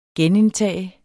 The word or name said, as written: GENINDTAGE